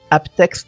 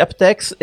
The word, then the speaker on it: aptX: Ah, toi, tu le prononces Aptex alors ouais, les Américains, enfin en anglais on dit Aptex et en français je sais qu'il y en a beaucoup qui disent APTX.